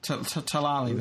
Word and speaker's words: Talalay: It's written by Stephen Moffat and directed by Rachel Talalay, if I'm pronouncing her name right. – Yeah. Come on, do it for us. – I thought that in that docu that everybody was saying Talalay. – Talalay, then.